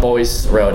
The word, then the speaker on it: Bois: John Boyce or John Blob? It's Bois.